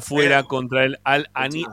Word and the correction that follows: Al Ain: Al no, Al Ain, ahí lo dije bien.